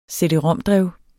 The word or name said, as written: cd-rom-drev